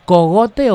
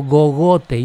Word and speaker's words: cogote: ¿Cogote o gogote? Yo digo que es cogote.